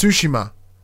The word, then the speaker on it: Tsushima: We've been corrected. It's Tsushima.